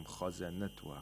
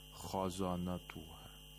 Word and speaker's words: خزنتها: وقال لهم خزنتها, خزنتها, again, خاء is a letter of استعلاء, خزنتها. And all the other letters in this word are letters of استفال, so you have to pronounce them with ترقيق, خزنتها. We don't say خزانتها, okay?